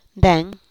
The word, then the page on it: nden: Teotitlán del Valle Zapotec